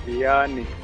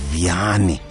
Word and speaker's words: Vianney: Uh, did I pronounce that correctly? – Vianney. Oh, Vianney. – I, yeah. V I A N N E Y.